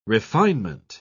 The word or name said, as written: refinement